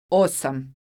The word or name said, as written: osam